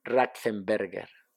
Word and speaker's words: Ratzenberger: Raxenberger. A ah, que lo dije bien, a ah, que lo dije bien. – Ahí vamos a ver. Roland Ratzenberger, él estaba corriendo para Ford.